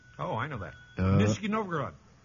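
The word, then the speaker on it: Nizhny Novgorod (Нижний Новгород): Nizhny Novgorod.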